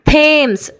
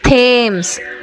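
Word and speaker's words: Thames: ಇನ್ನು ಕೆಲವು ಸಲ ಟಿ ಎಚ್ ಕನ್ನಡದ ಥದಾಗೆ ಉಚ್ಚಾರ ಆಗುತ್ತೆ. ಥೇಮ್ಸ್, T H A M E S, T H A M E S, ಥೇಮ್ಸ್. ಟಿ ಎಚ್ ಒ ಎಮ್ ಎ ಎಸ್, ಥಾಮಸ್, ಟಿ ಎಚ್ ಒ ಎಮ್ ಎ ಎಸ್, ಥಾಮಸ್. ನೋಡಿ, ಕನ್ನಡದಲ್ಲಿ ಥ ಉಚ್ಚಾರ ಮಾಡ್ತೀವಲ್ಲ, ಅದೇ ರೀತಿ ಟಿ ಎಚ್ನ ಉಚ್ಚಾರ ಬಂತು.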